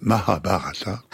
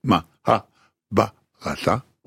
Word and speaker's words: Mahabharata: Et elle prononce Mahabharata comme il faut. C'est-à-dire qu'il y a deux accents toniques, Mahabharata, oui. Et c'est très difficile à dire.